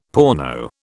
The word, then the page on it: porno: British English